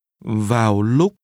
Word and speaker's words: vào lúc: Vào lúc. Lúc. Vào. Vào lúc. Vào lúc. – This is pronounced with low falling. – Vào. – And high rising tones. – Lúc. Vào lúc.